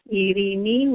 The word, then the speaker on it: Eirini: Yes, it's E-I-R-I-N-I, and it's pronounced Eirini with a rolling R, or Eirini, and it means peace.